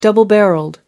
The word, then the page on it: double barrelled: American English